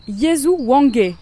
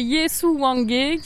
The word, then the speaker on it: Yesu Wange: Yesu Wange, je l'ai bien dit ? – Alors presque. C'est Yesu Wange qui veut dire « mon Jésus » en Luganda.